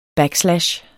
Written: backslash

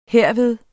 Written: herved

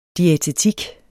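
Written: DIÆTETIK

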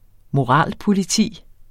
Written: moralpoliti

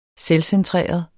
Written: selvcentreret

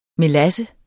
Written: melasse

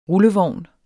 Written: rullevogn

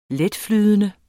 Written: letflydende

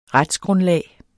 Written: retsgrundlag

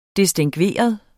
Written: distingveret

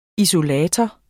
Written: isolator

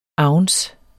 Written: ounce

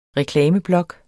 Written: reklameblok

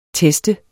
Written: teste

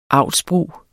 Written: avlsbrug

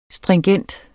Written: stringent

